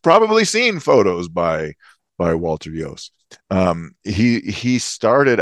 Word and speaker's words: Iooss: Let's move on to spotlight on Walter Hughes. – Yes. – Yost, excuse me, Yost.